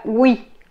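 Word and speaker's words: oui: Entonces, tenemos primero una que se escribe W y se pronuncia, por ejemplo, para la palabra wi, wi, wi. Es como si pronunciaran una W, como en weekend, pero es ahora con la escritura O-U. Wi, west, west.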